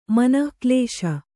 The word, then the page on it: ಮನೆಗೆಲಸ: manegelasa